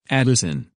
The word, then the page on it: Addison: English